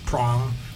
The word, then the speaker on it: Prong: Prong rhymes with wrong.